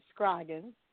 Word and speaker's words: Scroggins: You said it right, Scroggins.